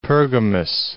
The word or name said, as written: Pergamus